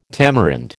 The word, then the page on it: tamarind: American English